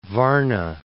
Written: Varna

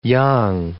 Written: Yang

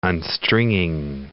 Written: unstringing

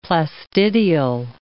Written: plastidial

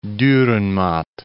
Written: Durrenmatt

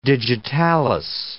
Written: digitalis

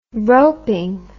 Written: roping

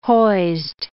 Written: hoised

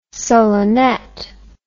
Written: solenette

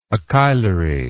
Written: achilary

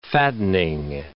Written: fattening

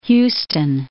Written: Huston

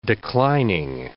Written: declining

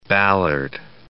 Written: Ballard

